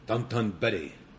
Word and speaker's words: Dotonbori: So it's funny because like now I got really used to pronouncing things there the way they do in Japan. So like there's this big market area. The big place you go in Osaka is called Dotonbori, but you go Dotonbori.